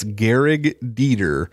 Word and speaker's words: Gehrig Dieter: I could never, until I saw his name in print, I thought they just didn't know how to pronounce Greg anymore, but it wasn't. It's Gehrig Dieter, and yeah.